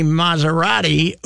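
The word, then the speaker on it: Massarotti: And my pal Tony Maserati, who is a... – It's spelled like the car? – No, it's SS, but it's Maz.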